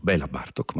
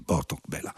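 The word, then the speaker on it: Bartók Béla: Noi semplicemente diciamo Béla Bartók, ma la pronuncia ungherese, lingua nella quale si pronuncia sempre prima il cognome e dopo il nome, vorrebbe così, Botok Béla.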